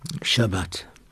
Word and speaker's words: Shabbat: Shabbat.